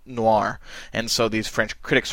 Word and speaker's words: noir: Noir, of course, is the French word for black, only they pronounce it with a different sounding R that I'm not going to try and emulate.